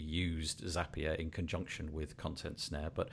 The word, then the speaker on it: Zapier: They're saying it wrong. You've got that absolutely right. It's Zapier. – Is it? Because it's a zap, right? And you don't you don't create a zape.